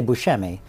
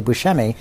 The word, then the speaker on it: Buscemi: But the correct, Sicilian pronunciation is Buscemi. So when people say Buscemi, that's very close to how I think what the real pronunciation is.